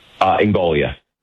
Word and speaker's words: Ingoglia: Am I pronouncing the last name correctly, sir? – Ingoglia. – Oh, the second G is silent.